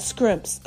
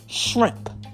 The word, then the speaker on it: shrimp: Shrimp. Not scrimps. Okay. Let's get the pronunciation correct. Shrimp.